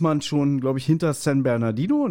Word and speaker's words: San Bernardino: Habe ich es falsch ausgesprochen? – San nee, Bernardino, hast, ne? Nein, du hast es richtig ausgesprochen.